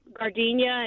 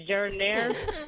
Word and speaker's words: giardiniera: I had this roommate in college that mispronounced so many things, but the two I remember most is she'd always order Italian beef with gardenia instead of giardiniera.